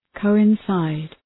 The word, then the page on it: coincide: ,kəʋın’saıd